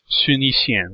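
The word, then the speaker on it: Sun Yixian (孫逸仙): Like, so Sun Yat-sen is not known as Sun Yat-sen in Chinese, or Sun Yixian, which would be the Mandarin pronunciation of that.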